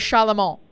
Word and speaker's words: Charlemont: So when you moved there, Todd, they told you it was Charlemont. – Yeah. – So it is the French way. – Yes. – So there's no. So we're not pronouncing the T. We're not meant to be pronouncing it.